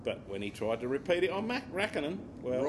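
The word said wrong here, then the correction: Räikkönen: What's it? What are you... It's Raikkonen. – Oh, right, okay. Raikkonen. – Can't you get the emphasis right? – No, I can't. – The right syllable.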